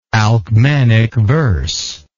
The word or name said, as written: alcmanic verse